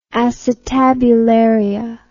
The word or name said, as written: acetabularia